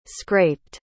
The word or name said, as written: scraped